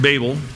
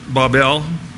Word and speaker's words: Babel: But Genesis chapter 10, and now then also chapter 11, we first hear of, again speaking Nebraskan, Babel, but Babel, as it's pronounced.